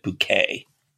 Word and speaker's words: Bucket: The last name is Bucket, but she pronounces it Bouquet.